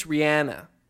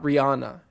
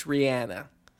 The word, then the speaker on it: Rihanna: Apparently, it's Rihanna, by the way. Not Rihanna. It's Rihanna. – Okay. – So that's how she even pronounces her name.